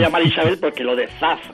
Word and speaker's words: Zaz: Zaz. – Zaz. Bueno, pues a mí me resulta muy difícil. – A ver, hago una Z, no la categórica castellana, ni siquiera la S líquida.